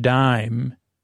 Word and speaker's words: paradigm: So I shifted the paradigm, P-A-R-A-D-I-G-M. So don't spell it dime, even though it's said dime, it's D-I-G-M. From the Greek, Daima, maybe D-E-I-G-M-A. Island, it's the letter S is silent, I-S-L-A-N-D.